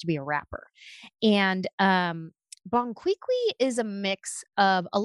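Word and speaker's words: Bon Qui Qui: Bonquiqui.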